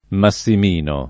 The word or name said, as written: Massimino